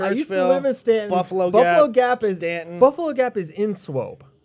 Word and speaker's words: Staunton: It's called Stanton, and it's spelled Staunton.